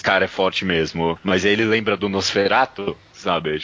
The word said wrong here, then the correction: Nosferatu: Tipo, esse ah, negócio eu falei necromante, é né, Nosferatu, falei errado.